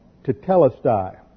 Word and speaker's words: tetelestai: And the last thing he said before he uh, died physically on the cross was the Greek word "tetelestai." Accent is on the second syllable.